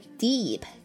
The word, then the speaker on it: deep: 那另外呢刚刚讲到的深呼吸啊叫做 take a deep breath，这里的 deep 所谓的这个 p 失去爆破，也就是呢保留发音的位置，但是不要完全的发出来，直接很顺的过渡到后面的 breath 来体会一下。 take a deep breath， take a deep breath，那我没有读成 take a deep breath 对吧，没有分开读，一定要读得非常顺啊。